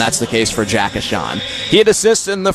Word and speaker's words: Ahcan: His last name is spelled A-H-C-A-N, pronounced Achan, from Savage, Minnesota, USA.